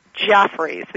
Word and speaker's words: Geoffreys: It's spelled the English way, Geoffreys, as in Sam. So Jeffries. People say Jeffries.